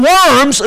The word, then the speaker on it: Worms: And when you look the word up, it's actually pronounced Worms or something like that, but V is actually, you know, the German language is W, so it doesn't sound that weird if you're German.